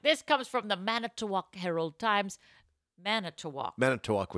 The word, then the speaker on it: Manitowoc: Manitowoc.